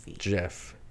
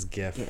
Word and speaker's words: GIF: It is not Jiff. It is GIF.